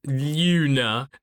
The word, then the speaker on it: Glüna: However, there's a little note in the manga that says the G in Luna is silent, and it also has an umlaut, so it's like U. I literally just looked up a pronunciation guide before we started recording, so it's like Ginka and Luna, and I'm sorry, but we're just gonna call her Luna.